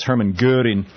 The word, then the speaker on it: Göring: It's, when it's anglicized, it's spelled G-O-R-I-N-G, but it's the O with the umlaut, the two dots over it, so it's actually pronounced so it's Hermann Göring.